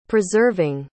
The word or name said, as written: preserving